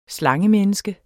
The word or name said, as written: SLANGEMENNESKER